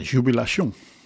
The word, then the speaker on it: Jubilation: En dat zul je dan wel ook wel op zijn Frans moeten uitspreken. Jubilation.